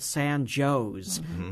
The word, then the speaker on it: San Jose: Downstate, it's pronounced Cairo. There's a community less than 2 hours east of Macomb called San Joe's, but it looks like San Jose. So if you're not from this region, you don't know it. You don't know that pronunciation.